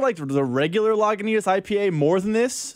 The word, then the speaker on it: Lagunitas: Lagunitas. – Lagunitas.